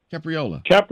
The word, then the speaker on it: Capriola: Capriola.